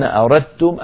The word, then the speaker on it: أردتم: لا شك أن أردتم أسهل، لأنني أنطق بحرف واحد مشدد وهو التاء، أردتم، فتحولت الدال إلى تاء، وأدغمنا التاء في التاء، فصار النطق بتاء مشددة.